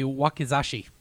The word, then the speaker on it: wakizashi: W-A-K-I-Z-A-S-H-I. – That would be Wakizashi.